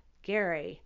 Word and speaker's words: Gerry: Gary, G-E-R-R-Y, but pronounced Gary.